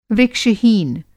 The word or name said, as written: वृक्ष-हीन